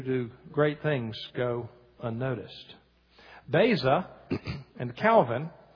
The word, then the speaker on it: Beza: His name is Theodore Beza. Theodore Beza, that's spelled B-E-Z-A.